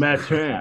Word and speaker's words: Mathurin: Yeah, your Benedict Mathurin pronunciation has to be much more French Canadian than that. It just Mathurin. – Has to be Mathurin. Mathurin. Mathurin is what we kind of were rolling with on the pod. Yeah. I like the T with the silent H.